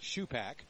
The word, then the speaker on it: Supak: On the mound, the big righty Trey Shupak, spells it S-U-P-A-K, pronounce it Shupak.